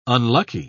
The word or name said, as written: unlucky